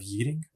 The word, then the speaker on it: Yeading: Prison scenes were filmed at HM Prison Oxford, and the match was filmed at the Warren, former home ground of Yeading.